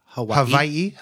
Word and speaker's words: Hawaii: It's pronounced Hawaii.